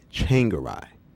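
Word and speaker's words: Tsvangirai: The MDC leader's last name is pronounced Changaray. That's right. Changaray. Changaray, rye like bread.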